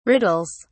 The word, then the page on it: riddles: British English